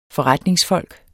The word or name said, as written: FORRETNINGSFOLK